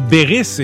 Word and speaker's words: Berries: Ça s'écrit Berries, comme Berries, mais ça se dit Berris et c'est très joli.